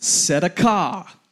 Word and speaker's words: tzedakah: This word is pronounced tzedakah, the Hebrew word for righteousness.